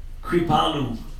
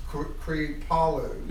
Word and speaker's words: Kripalu: And I want to go, tell me if I'm pronouncing this correctly, is it Kripalu? – Kripalu. – Kripalu. – Yeah, Kripa is mercy.